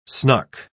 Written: snuck

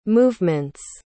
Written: movements

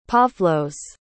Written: Pavlos

